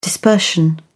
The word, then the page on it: dispersion: British English